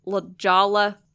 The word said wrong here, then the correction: La Jolla: She goes, it's pronounced La Jolla, which we never would have gotten.